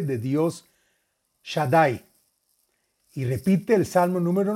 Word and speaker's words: Shaddai: Y es el santo nombre de Shin Dalet Yud, que se pronuncia Shaddai.